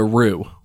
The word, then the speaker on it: roux: And this is called a roux, which is spelled R-O-U-X. It's a French word,